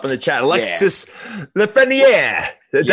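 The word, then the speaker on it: Lafrenière: No, but that is how you pronounce it, Lefreniere.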